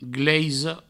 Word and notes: gleisa: gleiso

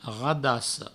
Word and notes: radassa: provençal; radasso

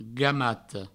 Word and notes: gamata: gamato

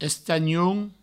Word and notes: estanhon: Provençal; estagnoun

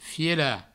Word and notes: fielar: fiela; Provençal

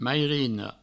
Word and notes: mairina: meirino

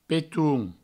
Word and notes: peton: Provençal; petoun

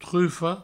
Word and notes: trufa: trufo

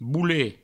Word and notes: bolet: boulet

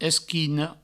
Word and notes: esquina: provençal; esquino

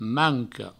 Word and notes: manca: manco

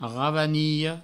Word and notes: ravanilha: ravaniho

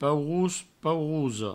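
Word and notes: paurós, paurosa: pourous, paurouso; provencal

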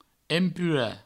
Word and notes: empurar: empura

